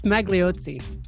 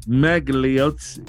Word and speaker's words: Magliozzi: How do you pronounce that? – I think it's Magliozzi. – Magliozzi. – Yeah, that sounds right.